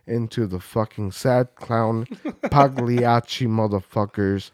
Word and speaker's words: Pagliacci: The G is silent. – Pagliacci.